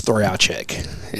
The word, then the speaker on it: Trojacek: Well, so the correct pronunciation is Trojacek.